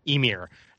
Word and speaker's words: Ymir: She prays to her father, the god, is it pronounced Ymir? – Ymir, to save her, and she vanishes in a flash of blue light.